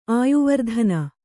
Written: ಆಯುವರ್ಧನ

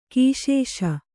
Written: ಘೈಸಾಸ

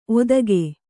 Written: ಒದಗೆ

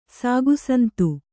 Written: ಸಾಗುಸಂತು

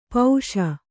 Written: ಪಾಶಿ

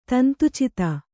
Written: ತಂತುಚಿತ

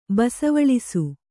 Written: ಬಸವಳಿಸು

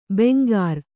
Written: ಬೆಂಗಾರ್

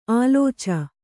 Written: ಆಲೋಚ

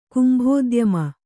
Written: ಕುಂಭೋದ್ಯಮ

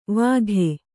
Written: ವಾಕು

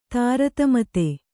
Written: ತಾರತಮತೆ

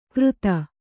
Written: ಕೃತ

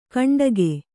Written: ಕುಂಟಿಗ

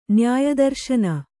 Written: ನ್ಯಾಯದರ್ಶನ

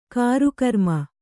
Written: ಗುರುಕ್ರಮ

